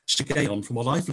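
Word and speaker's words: Shiggaion: If I pronounce it right, hooray, 10 points for me, but I might not. I think it's pronounced Shigeon. It is called a Shigeon of David.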